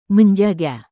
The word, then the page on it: menjaga: Indonesian